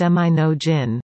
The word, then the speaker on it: Monongan: In December of 2022, the Monongan greenhouse, pronounced Minogin, with a hard G, had just been placed at Jocko Point, outside North Bay, still waiting for the final setup.